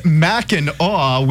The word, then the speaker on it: Mackinac: And by the way, it is pronounced Mackinac. We do things weird up in Michigan. It's spelled Mackinac, but pronounced Mackinac.